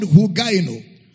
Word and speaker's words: hugaino: The word health here is the word hugaino. So let's examine Brother Paul's usage of the word hugaino. Hugaino in the Greek is spelled as H-U-G-A-I-N-O. Hugaino.